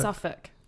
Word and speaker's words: Suffolk: It's called Suffolk, though, right? Suffolk? – Suffolk. – But it's spelled S-U-F-F. – There's an L in there, yeah.